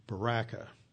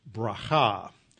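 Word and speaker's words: bracha (ברכה): Of course, you probably pronounced it baraka, but it's bracha.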